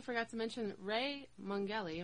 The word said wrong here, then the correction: Mongelli: Am I saying this right? – Mungelli. Yeah.